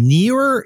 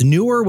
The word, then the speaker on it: Neewer: If you can order them, only because we've purchased at least a dozen sets of these in the past month, and I don't know how the company pronounced their name, Neewer, it's Neewer with the two E's, N-E-E-W-E-R.